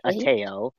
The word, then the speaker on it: ATAO: So ATAO, and what we pronounce it Ateo.